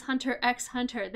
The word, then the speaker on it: Hunter x Hunter: And your first lesson is that the X is not pronounced. Yes, I know it says Hunter x Hunter. They don't pronounce the X.